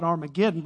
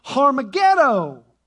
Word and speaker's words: Harmageddon: We call it Armageddon, but it's pronounced Harmageddon is going to play out.